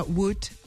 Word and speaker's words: would: I l'altre són algunes lletres mudes que no s'han de pronunciar, com, per exemple, would, could, la L, que nosaltres la veiem, no?, en la grafia, però no s'ha de dir.